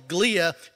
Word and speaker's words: glia: Here are also trillions of supportive cells in the brain called glia, G-L-I-A, I think it's pronounced.